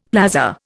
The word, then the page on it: plaza: Indian